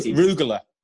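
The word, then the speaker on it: rugelach: But the first my first experience of Judaism was Rogaloch. Is that am I saying it right? Rogaloch? – The cake. – Yeah, the chocolate. Rugula.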